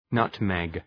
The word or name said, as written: nutmeg